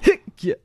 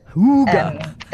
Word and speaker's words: hygge: You pronounce it hygge. – Oh. – Like, hygge. You, if you like, say H-O-O. G-G-A, hygge. – Hygge. – The Danish term of H-Y-G-G-E, it's hygge, pronounced hygge.